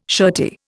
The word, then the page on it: shirty: Indian